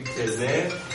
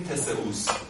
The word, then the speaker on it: تسئوس: این جالبه که تسئوس میاد، ببخشید مینوس میاد تسئوس رو که خودش هم که آره توی یه تلفظ میتونیم بهش بگیم تزه، توی این مخشش میتونیم بگیم تسئوس.